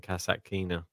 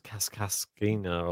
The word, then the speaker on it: Kasatkina: I finally pronounced her name right for the first time ever. – But, how did you used to say it? – I don't know. It's Kaskaskina or something. I was like Kasatkina.